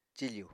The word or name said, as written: cifù